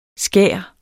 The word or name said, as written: skær